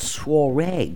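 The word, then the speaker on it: soirée: And this year, it's called Soiree.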